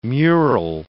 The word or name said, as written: mural